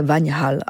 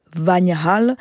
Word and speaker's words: Vanhal: Je vous propose d'écouter ce rondo de son deuxième quatuor à cordes, donc de Johann Baptist Vagnhall. Ça s'écrit V-A-N-H-A-L, toujours avec le quatuor Benevitz.